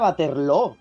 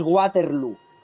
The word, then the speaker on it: Waterloo: Que se pronuncia Waterloo, porque es un término holandés, una palabra, no sé si flamenca, holandesa. Lo que quiero decir es que no es Waterloo.